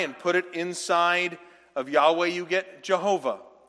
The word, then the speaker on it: Yahweh: And so if you'd like to spell it out better, so you remember how to say it, it's YAHWEH, Yahweh.